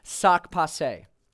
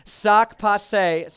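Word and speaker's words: sak pase: And if you want to say, like, what's up to someone, the Creole for what's up is sac passé. Okay, so let's try that again. Try that together. Okay, sac passé. So say it right after me. Sac passé.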